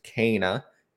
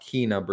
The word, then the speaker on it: Kena: Because I played Kena, Bridge of Spirits, and yes, it's pronounced Kena, not Kena Bridge of Spirits, as some people like to say.